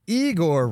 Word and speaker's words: Igor: Well, Igor rhymes with Seymour.